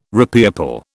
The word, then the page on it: repeople: British English